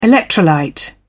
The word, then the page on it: electrolyte: British English